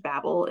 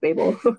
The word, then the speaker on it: Babel: Kuang, Rebecca Kuang, which I thought was pronounced Babel until I listened to the audio and it's actually pronounced Babel.